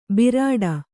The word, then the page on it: ಬಿರಾಡ: birāḍa